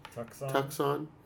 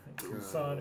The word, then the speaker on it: Tucson: Tucson. Tucson, Arizona.